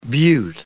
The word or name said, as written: beaut